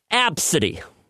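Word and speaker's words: Abcde: It's pronounced Absidy, which sounds a lot like Cassidy mixed with abscess, which isn't good, because neither...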